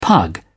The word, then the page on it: pug: British English